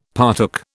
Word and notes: partook: British English

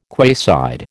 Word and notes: quayside: American English

